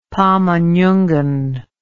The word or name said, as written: pamanyungan